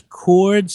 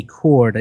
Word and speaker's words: Coard: James Cord, C-O-A-R-D, Cord, I think is how you pronounce it, a British actor.